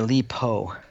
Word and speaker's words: Li Po: So that was Autumn Years by Autumn Air by Li Po. – Autumn Air by Li Po. How do I pronounce the author's name? Or how do I spell it? – Lipo.